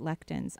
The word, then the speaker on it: lectins: Is that I want you to follow Dr. Gundry's diet, which means do not eat lectins. I think you have an intolerance to lectins. – To what? How do you say that? – Lectins.